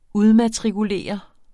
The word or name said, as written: udmatrikulere